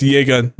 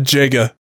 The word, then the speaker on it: Jega: And by the way, yes, it is pronounced Yega, not Jega.